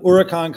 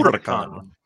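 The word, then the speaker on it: Huracán: Huracan, you're saying it correctly and I'm just not ever because I can't. – Huracan. – Huracan.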